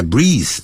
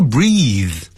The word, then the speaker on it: breathe: When I breathe, 当我呼吸的时候，我的胸口就感到有点痛。 – I feel a pain in my chest when I breathe. – 这个 breathe 是动词，所以不要念成 breath. – Breath 是 B-R-E-A-T-H, 是名词。